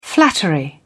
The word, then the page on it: flattery: British English